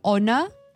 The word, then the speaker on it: Honor: It's written like Honor, like H-O-N-O-R, but it's pronounced as Honor.